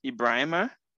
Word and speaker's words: Ibrahima: Uh, so Ibrahim is pronounced Ibrahima.